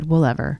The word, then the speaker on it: Woolever: They were like, how do you pronounce your name? And I said, Woolever.